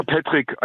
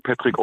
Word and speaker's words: Patrick: Ich, ähm ja, Patrick, einfach Patrick ausgesprochen. Patrick. Also Patrick geschrieben, aber Patrick ausgesprochen.